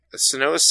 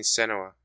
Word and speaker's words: Senua: I always heard people say Senua's. Are you saying it how they say it in the game, or are you just saying it how you assume it's pronounced? I'm legitimately curious. – How am I saying it? Because I'm playing Senua. I'm saying Senua. – That time it sounded like that, but before it sounded like you said something different.